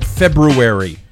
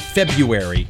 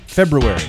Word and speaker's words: February: Notice I said February, not February. Say it right. February. – Yeah, you hit the R.